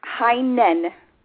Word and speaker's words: Heinen: Did I pronounce your name right? Did I butcher it? – Heinen.